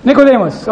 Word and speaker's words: Nicodemus: Nicodemus, okay.